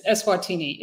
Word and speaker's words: Eswatini: It's Eswatini.